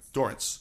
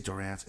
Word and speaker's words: Dorrance: I'm gonna say it wrong. – Dorrance. – Yeah. – I always say Dorrance. It's terrible.